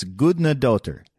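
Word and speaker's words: Guðnadóttir: So when I was pronouncing her name, I thought it was Gudnadottir or daughter. And so anyway, in the interview, I'm totally butchering it, and Robert will just take the reins on saying the name. But it's Gudnadottir.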